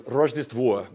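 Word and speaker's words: Rojdestvo (Рождество): This first one here says Rojdestvo, which is how you say Merry Christmas in Russian.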